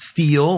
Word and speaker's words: Still: That's S-T-I-L-L, but it's pronounced Steele, like the Ghetto Boys song.